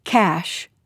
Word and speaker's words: cache: Well, I learned how to pronounce cash, C-A-C-H-E.